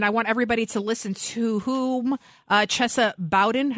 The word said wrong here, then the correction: Boudin: am I saying his name right, or Bowden? Bowden mm. blames for his uh, recall. – What is Bowden? How do you say it? Boudin. Boudin. Whatever.